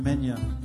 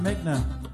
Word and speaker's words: Megna: On my street, a guy came down every Tuesday selling vegetables in his truck, an Italian man named Mr. Menya. We didn't know how to pronounce Menya. We called him Mr. Megna. That's what it said on his truck, Mr. Megna's, Megna's Produce.